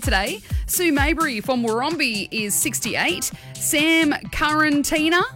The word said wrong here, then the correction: Karatiana: Did I say it right? – Karatiana, I think. – Karatiana. Sam Karatiana from Campbelltown is 41.